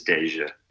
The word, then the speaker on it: Deyjah: But also, because I'm good at pronouncing names, D-E-Y-J-A-H is Deja.